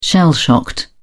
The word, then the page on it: shell shocked: British English